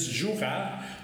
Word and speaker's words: Jura: Jura, se escreve, se diz Jurá, mas se escreve Jura, né?